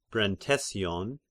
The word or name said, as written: Brentesion